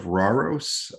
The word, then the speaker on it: Rauros: Uh, my next track is, uh, the band is called Falls of Rauros. I believe is how you say it. It's R A U R O S.